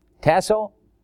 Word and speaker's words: tasso: Not sure I'm pronouncing that right, but how would you pronounce T-A-S, and S-O? Tasso? Good enough. I've got verification from a Greek scholar.